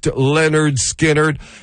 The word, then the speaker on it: Lynyrd Skynyrd: Lynyrd Skynyrd would release their debut album, pronounced Lynyrd Skynyrd, and a fine release for the debut album.